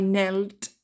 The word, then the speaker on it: knelt: To kneel, I knelt, knelt. Le K ne se prononce pas.